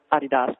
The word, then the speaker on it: Adidas: I think the coolest thing is if you watch those commercials, the Adidas commercials, the Adidas commercials, as we pronounce it here in the States, for Star Wars, there's a great cantina scene with Beckham and Snoop Dogg and Windows from Fanboys.